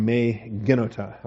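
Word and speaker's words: me genota (μὴ γένοιτο): Now we have language here, may it never be, which is our old favorite meganoito, or me genota, I guess, to give it a better pronunciation.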